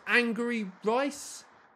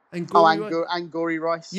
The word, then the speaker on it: Angourie Rice: Is it pronounced Angry Rice? – Angourie Rice.